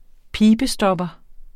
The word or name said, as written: pibestopper